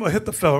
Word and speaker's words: Ahithophel: Is it Ahithophel? Am I saying his name wrong? Ahithophel's. Let's ask the oracle. Where's he at? Oh, there he goes back there. Oh, there you go. That's his name, Ahithophel, right? – 2 Samuel chapter 16 and verse 23.